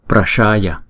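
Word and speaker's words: praśaya: và tiếng Phạn chúng ta đọc là Prasaya. Chữ S này chúng ta để cái đầu lưỡi ngay cái ở trong cái hàm răng của chúng ta, và chúng ta ểnh cái lưỡi lên, chúng ta đọc là sh sh Prashaya, Prashaya.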